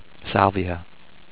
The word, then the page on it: Salvia: SAL-vee-uh